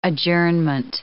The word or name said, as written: adjournment